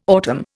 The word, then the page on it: autumn: Indian